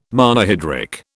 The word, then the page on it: Monohydric: British English